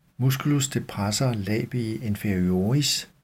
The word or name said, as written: m. depressor labii inferioris